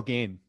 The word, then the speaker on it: Gane: It's Cyril Gane. – Cyril Gane. – Cyril, like G-A-I-N. That's perfect. – How you pronounce it.